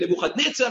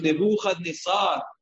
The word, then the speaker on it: Nebuchadnezzar: Most people say Nebuchadnezzar Rasha. That's a mispronunciation of his name. His name was not Nebuchadnezzar. His name was Nebuchadnezzar. Milera. Not Mile'el, not Nebuchadnezzar, Ela Nebuchadnezzar.